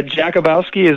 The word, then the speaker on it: Jakubowski: Yeah, Jakubowski is the correct pronunciation.